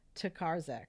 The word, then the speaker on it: Tokarczuk: The book is Drive Your Plow Over the Bones of the Dead by Olga Tokarczuk. I believe that's how you pronounce her name. – Yes, I believe so too.